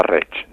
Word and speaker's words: Rey: y tenía un problema dentro de la marca, que es que Rey, como le decimos todos, no se pronuncia así en valenciano, se pronunciaba Reich, ¿no?